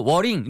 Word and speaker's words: worrying: Y로 끝나는 거, E 발음으로 끝나는 거는 ing 붙일 때 절대로 worrying 이렇게 하시면 안 돼요. Worry, 그 다음에 ing, 그래서 E 발음이 두 번이어야 돼요.